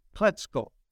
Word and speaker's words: Klecko: Oh, it's spelled exactly like it is, but it's an asterisk over the O, and it's Klecko is how it's pronounced in Polish.